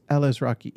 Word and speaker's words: Alazraqui: bueno Al- yeah, uh, Alice Alazraqui. Yeah, that's how you say his name.